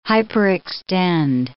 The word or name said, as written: hyperextend